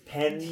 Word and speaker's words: penne: Anytime there's a double consonant or in Italian, it's penne, there's a pause and an emphasis on the letter, so it lasts longer.